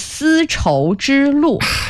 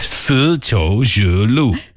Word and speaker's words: 丝绸之路: It is four characters, and it is 丝绸之路. – 丝绸之路.